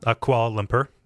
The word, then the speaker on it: Kuala Lumpur: Kuala Lumpur, Malaysia. – Kuala Lumpur.